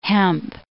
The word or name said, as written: hemp